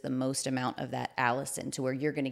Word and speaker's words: allicin: But allicin, it's A-L-L-I-C-I-N. It's, I guess, pronounced allicin.